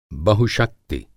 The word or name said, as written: बहुशक्ति